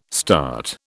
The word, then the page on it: START: British English